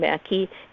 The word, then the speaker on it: Maki: is David Mackey, and he's part of the Historical Society as well, and that's spelled M-A-K-I. It's the Finnish name Mackey.